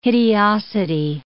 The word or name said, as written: hideosity